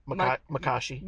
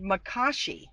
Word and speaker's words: Makashi: Makashi. – Makashi. – Right, Makashi.